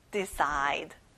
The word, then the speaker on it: decide: 자, C 발음은 크가 아니라 S 소리가 나네요. Decide.